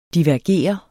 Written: DIVERGER

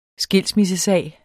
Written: skilsmissesag

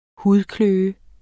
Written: hudkløe

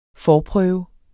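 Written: FORPRØVE

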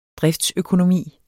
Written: driftsøkonomi

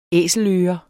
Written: æseløre

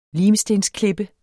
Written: limstensklippe

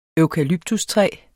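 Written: eukalyptustræ

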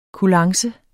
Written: kulance

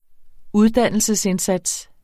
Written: uddannelsesindsats

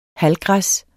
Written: halvgræs

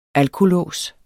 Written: alkolås

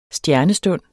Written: STJERNESTUND

